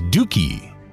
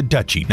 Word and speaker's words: duchy: I instructed our producer to pronounce it as dookie. That was incorrect. The correct pronunciation for a realm ruled by a duke is a duchy, not a dookie.